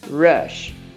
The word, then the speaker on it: rush: Mas muito cuidado com a pronúncia. Hurry tem um som de R, porque ele começa com H, in a hurry. Mas o outro, ele começa com R, e aí ele não tem um som de R com H, H. Ele tem um R mordido, rush, in a rush, ok?